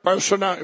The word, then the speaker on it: Bolsonaro: Bolsonaro, if I say it right, Bolsonaro.